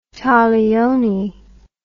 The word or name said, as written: taglioni